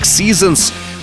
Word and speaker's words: SZNZ: Це десятий міні-альбом в кар'єрі Wizard і четвертий з чотирьох міні-альбомів у їхньому проєкті SZNZ, вимовляється як Seasons – перекладається як пори року.